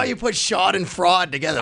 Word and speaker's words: Schadenfreude: I heard Jeremy say "shod and fraud." – Is that how you say it? Schadenfreude? – So here, Schadenfreude. – Here we go. – Schadenfreude.